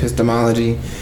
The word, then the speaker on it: epistemology: Anyway, uh, what is standpoint epistemology? Did I say that right? Yep, standpoint okay. epistemology.